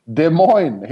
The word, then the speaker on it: Des Moines: Det är inte varje dag man är i Des Moines eller var det var någonstans. – Des Moines. Des Moines heter det.